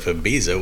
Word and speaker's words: Ibiza: Is that why in Spanish, proper Spanish, like the island of Ibiza, which is spelled with a Z, they say Ibiza. – That's Spanish because everything is with a TH.